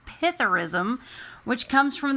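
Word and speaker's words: psithurism: Which comes, it's P-S-I-T-H-U-R-I-S-M. – I'm sure the P isn't. – The S is silent.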